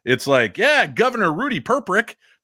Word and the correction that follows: Perpich: Perpich uh, yeah, Greg Gagne, uh, Gagne.